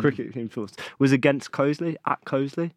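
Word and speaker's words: Coseley: Even the occasional cricket talk takes me back to my season's playing for Cosley. Is that the right pronunciation? Cosley? – Cosley!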